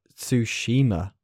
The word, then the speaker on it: Tsushima: There are going to be cameos such as God of War Ragnarok, Sly Cooper, Resistance, Shadow of the Colossus, Horizon, and Ghost of Tsushima. That's how you pronounce it, Davey.